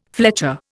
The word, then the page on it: fletcher: Indian